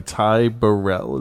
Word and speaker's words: Ty Burrell: Ty Burrell. Is that how you say his name? – Yeah.